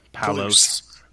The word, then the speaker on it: Palouse: Palouse, I said it right? – Palouse.